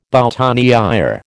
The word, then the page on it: boutonnière: American English